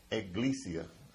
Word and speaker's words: ecclesia: and I believe it's pronounced ecclesia. Ecclesia, yes.